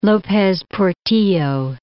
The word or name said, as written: Lopez Portillo